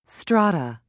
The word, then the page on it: strata: British English